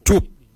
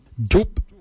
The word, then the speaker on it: djob: Kort is spelled G-O-O-R-D, kort. Kort is your heart. For kidney, we say jup. Jup is spelled D-J-O-B, jup. Jup is your kidney. Lungs, we say wal. Wal is your lungs. Wal is spelled W-A-L-Y, wal.